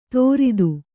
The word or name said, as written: ತೋರಿತ್ತು